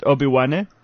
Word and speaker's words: Obi-Wan: Is it right that I pronounce it Obi-Wan? – Yes, perfect.